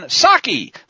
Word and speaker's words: Psaki: White House Press Secretary Jen Psaki, the P is silent like in Resident,